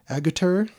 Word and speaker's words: Agutter: Agutter. Okay, Jenny Agutter.